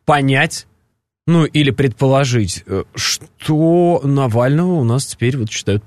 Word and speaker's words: Навального: Основателя фонда борьбы с коррупцией Алексея Навального атаковали неизвестные, метнув в него пару тортов.